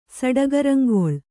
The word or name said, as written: ಸಡಗರಂಗೊಳ್